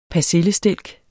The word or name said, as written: persillestilk